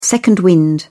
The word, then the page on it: second wind: British English